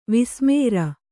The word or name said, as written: ವಿಸ್ಮೇರ